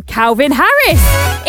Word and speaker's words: Calvin Harris: Said his name wrong. Calvin Harris.